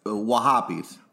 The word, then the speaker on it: Wahhabis: I think you said that wrong. It's Wahhabis.